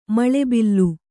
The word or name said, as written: ಮೌಲಬಲ